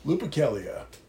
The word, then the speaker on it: Lupercalia: Can you give me the correct pronunciation of the festivity? – Lupercalia.